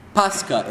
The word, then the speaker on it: Pascha: Pascha in Greek. If you can pronounce that funny ch sound, it's Pascha. If you can't, Pascha. Pascha.